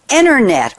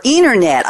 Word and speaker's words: Enernet: It's called Enernet or Enernet, I'll let you pronounce it any way you want, E-N-E-R-N-E-T, is positioned to transform our lives, perhaps even on a larger scale than the internet before it.